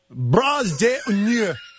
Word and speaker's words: bras d'honneur: It was, as I said, bras d'honneur.